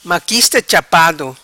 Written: Maquista Chapado

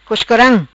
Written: Coscoram

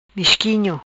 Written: Misquinho

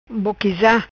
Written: Boquisa

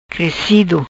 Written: Crecido

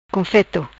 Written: Confeto

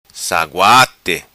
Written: Saguati

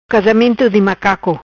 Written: Casamento di macaco

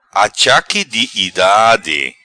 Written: Achaque di idade

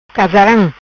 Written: Casaram

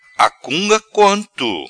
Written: Acunga quanto

